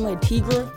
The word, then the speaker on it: Le Tigre: Tomorrow I'm rocking the green Le Tigres. I'm rocking the green Le Tigre with... – Oh, hold on. It's not Le. Le Tigre. Like, say it like it's one word.